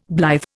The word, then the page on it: blithe: Indian